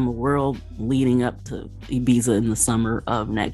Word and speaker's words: Ibiza: All right, and the Black Music Summit is heading to Ibiza, or Ibiza for some of us U.S. people, as we pronounce it.